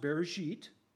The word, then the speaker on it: Bereshit: In that agreement, God promised in the book of Genesis, chapter 12, verse 3, in Hebrew it's pronounced Bereshit, I will bless those who bless you, and I will curse those who curse you.